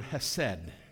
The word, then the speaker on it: hesed: Now, the word kindness is the word hesed, the Hebrew word hesed. Am I pronouncing that right? Hesed? Hesed. Hesed. All right, a little more hes.